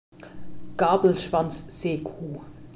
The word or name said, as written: Gabelschwanzseekuh